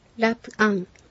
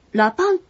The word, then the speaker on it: ラプアン: ラプアン。 – ラプアン。私たちは高いところから降りる。この場合も、ラパンという発音になることもあります。